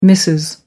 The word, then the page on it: mrs. gandhi: American English